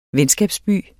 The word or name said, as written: venskabsby